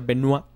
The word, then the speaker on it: Benoit: Bueno, y como ya vieron, pues en el título, vamos a hablar acerca de Chris Benoit se escribe Benoit, pero se pronuncia Benoit.